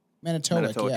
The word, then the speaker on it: Manitowoc: Manitowoc? – Fucking Wisconsin. Manitowoc, yeah.